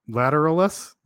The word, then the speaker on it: Lateralus: Lateralus? – Yeah.